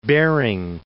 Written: Behring

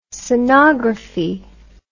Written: sonography

